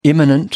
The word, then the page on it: imminent: American English